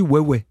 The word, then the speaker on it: Huawei: Les publicités, il est écrit, si je le fais phonétiquement, Huawei. Mais en fait, on dit Huawei.